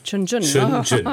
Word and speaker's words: Shenzhen: Und vor 2 Jahren veröffentlichten sie Shenzhen, wenn ich das richtig ausspreche, Zukunft in China, über diese Metropole Shenzhen. Oder wie sprechen Sie im Süden China? – Shenzhen. – Ah, ganz falsch, okay.